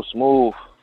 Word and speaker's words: Smoovv: And then I just started making tracks after that and came up with the name Real Smooth.